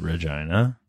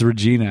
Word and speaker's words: Regina: It's Regina, actually. So you mispronounced it. You're probably going to have to bleep that out. – Sorry, Vagina. Vagina is the capital. Yeah, because it's a city that rhymes with fun.